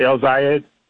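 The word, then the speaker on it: El-Sayed: like I hope I'm saying his name right. El Zayed. – That's right. Okay.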